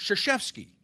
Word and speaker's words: Krzyzewski: it would be Krzyzewski, but it's Krzyzewski.